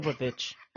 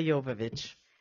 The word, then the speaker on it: Jovovich: Mila Jovovich. – I hear it. – Mila Jovovich. – Okay. – Let's say that again. Okay, so two Joan of Arc projects come out. One is on film, Mila Jovovich, in the film version.